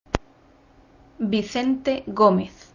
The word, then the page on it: Vicente Gomez: Spanish